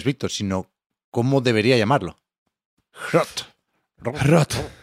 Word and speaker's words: HROT: Rot. – H-R-O-T, todo en mayúsculas, Rot.